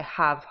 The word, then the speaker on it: ha: And that's spelt H-A, but with a silent H.